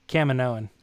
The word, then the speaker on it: Caminoan: Caminoan.